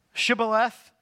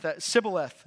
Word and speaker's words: Shibboleth: You see their pronunciation of that word, Shibboleth, indicated their dialect was identified as them being from the land of Ephraim. They couldn't say the SH, they could just say the S. They said Shibboleth instead of Shibboleth.